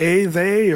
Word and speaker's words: Athey: A, they, or, and I say, well, it sounds like atheist, but we are not atheists. – That's right. – But it's Athey.